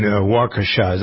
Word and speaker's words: Waukesha: I see some senators on both sides are warning against the attempts to exploit the tragedy in Waukesha. Is that how you pronounce it? I guess it is, right? – Yeah.